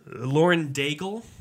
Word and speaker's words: Lauren Daigle: It is Lauren Daigle.